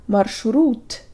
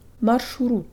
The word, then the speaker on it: маршрут: Not marshrut, but marshurut. Marshurut.